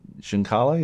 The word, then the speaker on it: Giancarlo: Giancarlo, yeah.